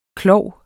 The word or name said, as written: klov